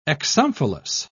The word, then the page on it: exomphalos: British English